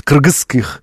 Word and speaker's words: кыргызский: Я когда-то слышал выступление одного из, по-моему, кыргызских, вот так правильно говорить, кыргызских деятелей, по-моему, политических, я не ошибаюсь, я в интернете находил.